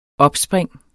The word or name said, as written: opspring